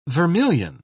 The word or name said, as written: vermillion